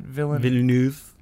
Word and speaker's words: Villeneuve: Which, directed by Villeneuve. How do you say it? – Villeneuve. – Yeah. – Denis Villeneuve.